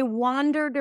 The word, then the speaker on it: wander: And wander, I wandered around the streets last night. Wander, that's an ah sound. Wander, wander, wonder, wander. So the one spelled with an O is a cup sound.